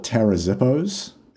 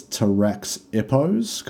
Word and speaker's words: Taraxippos: The name, I want to call it Tarazippos and pronounce the X like a Z, because otherwise it's Taraxippos, because there's a double P. – Yeah. – Tarazippos? Tarazippos. Tara's Hippos. – T-A-R-A-X-I-P-P-O-S.